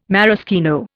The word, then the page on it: maraschino: Indian